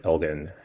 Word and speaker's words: Elgin: and Lord Elgin had a hard G.